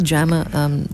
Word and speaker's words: JAMA: Is that JAMA, Dr. Rabin? – Yes.